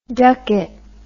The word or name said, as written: ducket